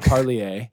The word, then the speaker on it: Parlier: I say the French pronunciation, Parlier.